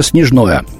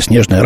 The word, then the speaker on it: Снежное: Кроме того, местом пуска в документе назван поселок Зарощенское, тогда как ранее неоднократно сообщалось, что пусковая установка была расположена неподалеку от поселка Снежное, или Снежное, разное ударение.